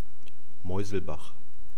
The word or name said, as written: meuselbach